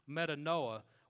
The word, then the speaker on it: metanoia: The third one is a New Testament word, and it's pronounced metanoia, which means to change.